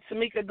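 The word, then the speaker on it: Temekia: I'm on Facebook with my name, Tamika Glenn. It's spelled uniquely. It's T-E-M-E-K-I-A.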